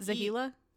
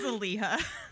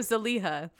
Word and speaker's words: Zeliha: Not Zahila? – No, Z-E-L-I-H-A. – Oh, my bad. – It truly doesn't matter because I just said like Zamina, but that's how it's spelled. So I think it's Zaliha.